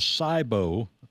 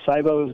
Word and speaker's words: SIBO: SIBO. I guess I'm saying that correctly, S-I-B-O, which stands for Small Intestinal Bacterial Overgrowth.